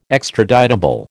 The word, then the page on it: extraditable: American English